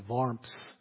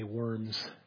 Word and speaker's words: Worms: You know that in 1521 he was brought to a place called Worms. That's how they say it in German, but we would say Worms.